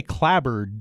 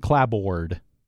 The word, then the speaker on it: clapboard: Clab. Well, we say clabbered, don't we? It's That O-A is... We aren't saying clabboard. – Right. Clabbered. It becomes like a schwa sound. Sound. Yep. That's exactly it. Now we have another toughie here. B-L-A-C-K... G-U-A-R-D. – B-L-A-C-K-G-U-A-R-D.